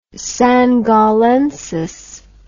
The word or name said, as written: Sangallensis